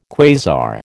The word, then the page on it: quasar: American English